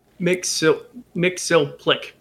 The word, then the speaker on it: Mxyzptlk: That's not Mixoplick. No, it's MXYZPTLK. I don't know. Maybe that's how you pronounce it. Ultraman. What? That's copyrighted. – Mixoplick. Yeah, Mr. Mixoplick. Yeah, that's how it's pronounced.